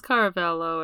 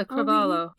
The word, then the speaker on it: Cravalho: It's the girl from Moana, Auli'i Cravalho. I'm hoping I'm saying her name right. – Cravalho? I thought it was Caravello or something. How is it spelt? – Where's the page for this movie? – Oh, yeah, Cravalho. – Cravalho.